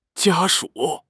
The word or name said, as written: 家属